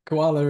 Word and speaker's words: koala: So instead of gula, it became guala, and finally adopting the word koala.